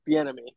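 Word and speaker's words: Bieniemy: Yeah, it's Like, all these guys are trying to suck off Beniemy, however you say his name, from Washington. – the enemy. – Maybe that off Bienemy. Yeah, there we go.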